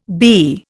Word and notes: bee: Indian

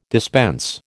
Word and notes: dispense: American English

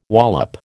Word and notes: wallop: American English

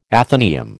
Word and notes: athenaeum: American English

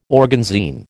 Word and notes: organzine: American English

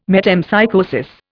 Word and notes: metempsychosis: Indian

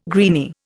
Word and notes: greeny: Indian

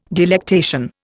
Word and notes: delectation: Indian

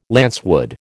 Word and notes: lancewood: American English

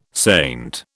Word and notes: saint: British English

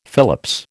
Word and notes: Phillips: American English

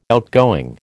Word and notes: outgoing: American English

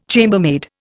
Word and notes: chambermaid: Indian

